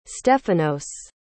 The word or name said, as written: stefanos